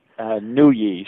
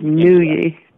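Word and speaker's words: Nooyi: Nuyi. She's from Nuyi.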